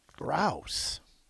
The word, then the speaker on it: Rouse: Roos? – Rouse. – Yeah, rhymes with house. Rouse. Ah.